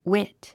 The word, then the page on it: wit: wít; ウィット